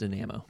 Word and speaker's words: Dynamo: Dynamo team that sits 12th of 13th, 13 in the West standings. – It's pronounced Dynamo, but okay.